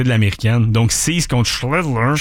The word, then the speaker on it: Schlittler: Schlittler. J'aime ça de la manière tu l'as très bien prononcé.